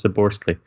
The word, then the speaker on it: Szoboszlai: Szoboszlai, I think, is the pronunciation I got. I asked a Hungarian journalist that I follow on Twitter, I asked him for a pronunciation. I think it was Szoboszlai.